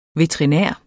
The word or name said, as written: veterinær